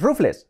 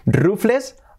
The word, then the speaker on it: Ruffles: Pues porque no se dice Ruffles, agarraos que se dice Ruffles. Ruffles. Una bolsa de Ruffles. Ruffles. Sí, sí. Ruffle es como rizado, ondulado, pues de ahí viene el nombre.